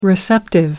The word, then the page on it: receptive: American English